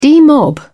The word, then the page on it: demob: British English